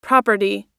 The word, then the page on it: property: American English